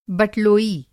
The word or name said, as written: बटलोई